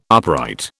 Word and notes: upright: British English

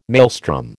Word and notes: maelstrom: American English

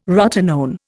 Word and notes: rotenone: Indian

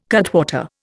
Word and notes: cutwater: Indian

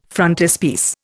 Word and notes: frontispiece: Indian

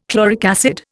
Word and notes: chloric acid: Indian

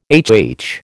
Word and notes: HH: American English

